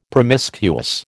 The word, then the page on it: promiscuous: American English